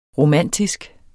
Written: romantisk

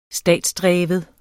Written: statsdreven

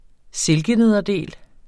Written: silkenederdel

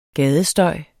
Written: gadestøj

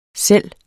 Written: selv

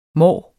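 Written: måneår

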